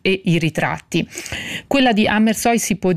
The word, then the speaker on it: Amersfoort: Gli interni domestici, per il quale Amersfoort, e la pronuncia rassicuro chi ci ascolta, io l'ho verificata con la curatrice Sandra Gianfreda e quindi dirò Amersfoort,